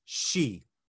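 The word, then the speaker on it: sí: So the word for fairies and supernatural creatures in Irish is called an Ish Shi. Right? And so there are different creatures that are part of the Shi. Right? So we spell that in Irish S-I accent. In English, it's often spelled S-H-E-E, like the Banshee, right?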